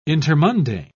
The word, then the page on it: intermundane: British English